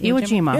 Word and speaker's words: Iwo Jima: Iwo Jima.